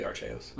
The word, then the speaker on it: Arceus: I believe it's now Arceus. – Oh, okay. – We're going to say it correctly, Arceus.